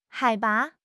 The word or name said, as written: 海拔